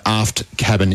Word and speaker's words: Aft Cabin: Pronunciation Aft Cabin. Now, man tells us they're Aft Cabin, as in you don't want to be too daft about things. So Aft Cabin is the pronunciation.